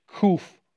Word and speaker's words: kuf: Take a look here at this kuf. Now, keep in mind that the kuf should be deeper in the throat. Kuf is how the word is pronounced.